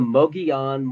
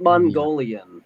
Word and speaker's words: Mogollon: It's the Mogollon Monster 100. – Mogollon. – Not Mongolian. Everybody says Mongolian Monster.